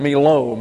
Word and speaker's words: melo: M-E-L-O, pronounced Melo.